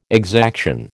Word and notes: exaction: American English

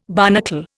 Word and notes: barnacle: Indian

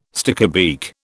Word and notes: stickybeak: British English

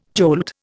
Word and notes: jolt: Indian

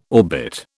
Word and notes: orbit: British English

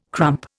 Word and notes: crump: Indian

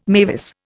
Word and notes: mavis: Indian

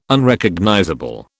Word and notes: Unrecognizable: British English

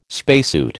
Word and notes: spacesuit: American English

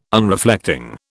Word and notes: Unreflecting: British English

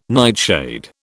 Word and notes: nightshade: British English

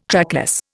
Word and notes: trackless: Indian

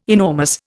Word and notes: enormous: Indian

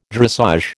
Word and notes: dressage: American English

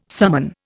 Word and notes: summon: Indian

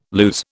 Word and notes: lues: British English

